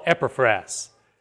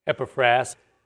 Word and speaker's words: Epaphras: First of all, Epaphras. Pronunciation is probably that way. Epaphras, although he's also called Epaphras.